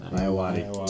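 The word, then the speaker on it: Ayoade: The watch stars Ben Stiller, Vince Vaughn, Jonah Hill, Richard Iotti, and Rosemary DeWitt. Did I say that wrong? Is it Iowati? – Iowati.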